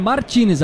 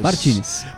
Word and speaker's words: Martínez: É Martínez ou Martinez, hein, Drayton? – A princípio é Martínez, a pronúncia dele, Martínez.